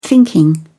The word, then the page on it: thinking: British English